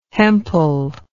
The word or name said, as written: hempel